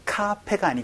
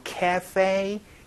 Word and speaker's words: cafe: 카페가 아니고 cafe. 요 발음 또 기억을 해 주시면 좋겠네요. 그래서 카페 라떼를 cafe latte 이렇게 얘기하는 발음도 같이 기억을 해 주시면 좋겠습니다. 자, 그럼 세 번째 문장으로는요.